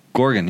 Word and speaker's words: Gorgon: Hope I'm pronouncing the last name right. – Oh, well, how do you spell it? – G-O-R-G-O-N. – Gorgon, yeah. – Yeah, Gorgon. Yeah. – Gorgon, okay.